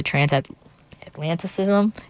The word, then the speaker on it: Transatlanticism: I was actually on the bus the other day and I pulled up the Transatlanticism.